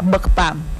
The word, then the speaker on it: bukpam: y en lengua Yocotán cráneo se pronuncia bukpam.